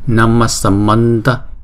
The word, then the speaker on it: Namasamanta: Thì khi chúng ta đọc ghép lại thì chữ H này chúng ta không có đọc lên là chữ H ha, chúng ta đọc là Namma Samanta Namma Samanta. Đó. Giống như là chữ S đó. Namma Samanta. Chữ H này là chúng ta đọc giống như là chữ S, Namasamanta, đúng không?